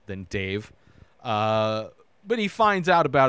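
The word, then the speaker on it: Dev: When I kept hearing them say Dave is this god, and the sequel, part two, is Dave, and it's D-E-V, pronounced by everybody in this movie Dave.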